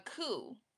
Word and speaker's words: coup: love, and I'll say I ain't know the word before, but a coup. And it's spelled COUP. Cool.